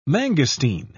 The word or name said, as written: mangosteen